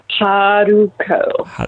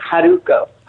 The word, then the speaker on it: Haruko: Haruko. – Haruko. – The R kind of sounds like a D, like Haruko.